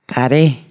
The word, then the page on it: corallo: Italian